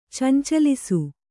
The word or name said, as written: ಚಂಚಲಿಸು